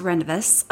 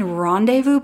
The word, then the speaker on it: Rendezvous: And my favorite beach is Rendezvous Beach, which, by the way, I used to pronounce Rendezvous. Have you ever seen how Rendezvous is spelled? My family made fun of me. I'm like, we're going to go to Rendezvous Beach. And they're like, you mean Rendezvous Beach?